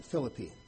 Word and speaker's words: Philippi: It's spelled the same as this, but they pronounce it Philippi. So I'm going to try to refer to this city as Philippi.